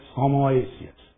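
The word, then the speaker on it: Homoiousios: Homoiousius.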